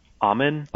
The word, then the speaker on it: Aumann: is it pronounced uh, Augman's agreement Amen, theorem? I think. – Say it. Amen? Amen. Okay,